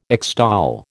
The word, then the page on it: extol: American English